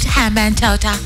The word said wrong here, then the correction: Hambantota: G- Gale, Hambantota, Hambantota. No, I was like, there has to be a way of changing that.